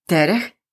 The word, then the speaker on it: trh: Trh, trhy, meaning markets. Markets. This one might be a little bit hard to pronounce for you. Yes, there are all consonants T, R, H, and it pronounces trh.